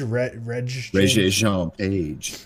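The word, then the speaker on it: Regé-Jean Page: Is it Reggie Jane Page? – Reggie Jean Page.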